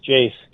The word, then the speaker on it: Jase: Your founder and CEO, is it Jace Medical? Am I saying that right? – Yes, that's right. It's Jace, J-A-S-E, great.